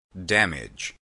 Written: damage

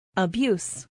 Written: abuse

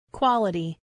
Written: quality

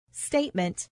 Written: statement

